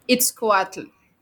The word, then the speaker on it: Itzcoatl: We cannot talk about all of them, but in chapter two, you tell us about the story of Itzcoatl, if I'm pronouncing that right. – Perfect.